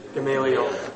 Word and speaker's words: Gamaliel: Gamaliel.